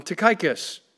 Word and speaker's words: Tychicus: Now, both Colossians and Philemon were delivered by a man named Tychicus. Some people call him Tychicus. So you put the accent on whatever syllable you want to.